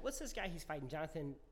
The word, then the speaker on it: Jonathan: Oh, it's a soft J. It's Jonathan. – Jonathan.